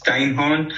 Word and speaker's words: Steinhorn: I think it's Steinhorn. – Yep. – It's one of the I hope I pronounced it right. – Yeah, perfect.